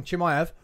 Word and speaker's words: Chimaev: Is Chimaev... I said it wrong. Chimaev.